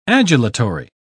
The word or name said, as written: adulatory